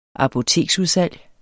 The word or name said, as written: apoteksudsalg